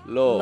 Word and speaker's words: Lauv: So I'm going to spell it. Okay, great. – L A U V. – Love.